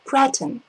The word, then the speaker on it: cretin: You mispronounced it. The stress, cretin. The stress is on the first syllable. Cretin, very important word.